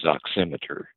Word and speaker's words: oximeter: And I believe the correct pronunciation on that is oximeter.